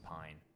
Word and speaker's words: Pyne: It's going to be Ottara, O-T-T-A-R-A. Middle name is Sacha, S-A-C-C-A. Last name is Pyne, P-Y-N-E.